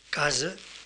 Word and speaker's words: casa: Así, por ejemplo, en la palabra casa, casa, esta, esta vocal final, aunque se escriba A, se pronuncia E casa.